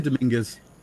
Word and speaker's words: Dominguez: You can say Dominguez. – Dominguez. – Like Dominguez Hills in California.